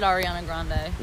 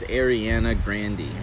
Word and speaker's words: Ariana Grande: Oh, Twitter said you may be pronouncing Ariana Grande's name wrong. – Well, she says it Ariana Grande. – It's Ariana Grande.